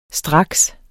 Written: straks

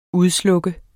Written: udslukke

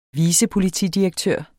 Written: vicepolitidirektør

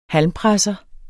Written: halmpresser

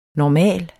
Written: NORMALERNES

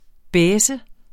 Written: baisse

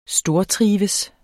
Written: stortrives

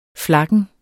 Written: flakken

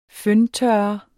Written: føntørrer

